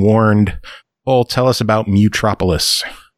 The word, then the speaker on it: Mutropolis: Metropolis? – No, it's Mutropolis. – Mutropolis. Really? – Yeah, surprise, bitch. – That is shocking. I thought it was Metropolis.